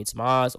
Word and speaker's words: Tomas: And I was like, if you know me, first of all, don't call me Thomas. I don't like that. If you know how to pronounce my name, call me Tomas, or you can just call me literally the letter T, like either or.